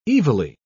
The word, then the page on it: evilly: British English